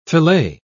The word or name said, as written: filet